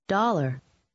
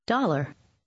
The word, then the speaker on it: dollar: Dollar. One. One. $1. – Обратите внимание на английское произношение хорошо знакомого вам слова «доллар». Скажите это слово еще раз. – Dollar. Dollar.